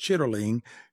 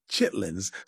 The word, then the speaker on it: chitterling: Even though the word is more accurately pronounced chitterling, we say chitlins.